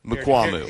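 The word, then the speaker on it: Mukuamu: Did I butcher it? Did I get close? – If you go to Pro Football Reference Wikipedia, Mukwamu. – Wikipedia. – All right, I was way off.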